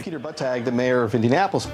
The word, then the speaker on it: Buttigieg: Anche in America c'è questo problema di come pronunciare Pete Buttigieg.